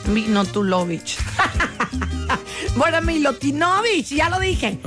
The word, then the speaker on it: Milutinovic: Estoy diciendo Minotuvoch, ¿está bien? Minotuno. – Es que así no se pronuncia, así se escribe. – Mino. – Ahí te voy. Bora Milutinovic.